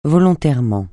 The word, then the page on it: volontairement: francais